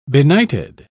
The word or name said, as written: benighted